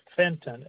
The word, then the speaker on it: Fenton: It's Fenton, F as in Frank, E-N-T-O-N.